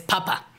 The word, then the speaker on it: Papa: Por ejemplo, la palabra alemana para decir papá es papa. Papa. Con la primera sílaba acentuada, como le dice Eleven a papa en Stranger Things.